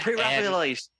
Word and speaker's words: Pre-Raphaelite: Pre-Raphaelite.